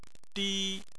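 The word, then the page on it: di: tone 1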